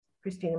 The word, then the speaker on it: Christina: I'm on Facebook, and I'm on LinkedIn, Christina Martel.